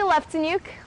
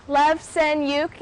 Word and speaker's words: Leftenuk: Hi, my name is Jessica Leftenuk. Leftenuk, it sounds exactly how it's spelt.